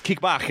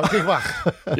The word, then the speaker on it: Kickbach: Excuse me, it's pronounced Kickbach. It's German. – Oh, Kickbach. – Yeah, it's not Kickback.